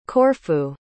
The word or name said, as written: Corfu